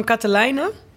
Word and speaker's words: Katelijne: Even van Katelijne. Of leg ik daar ook de klemtoon verkeerd? Nee, dit gaat heel goed.